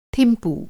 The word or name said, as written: थिम्पू